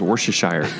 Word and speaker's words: Worcestershire: Ew, Mia Worcestershire Shakowski? – Yes. But honestly, when... – It's pronounced Worcestershire. – Oh, Worcestershire. Worcestershire Shire.